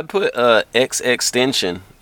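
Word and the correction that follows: XXXTentacion: X XXX Tentacion.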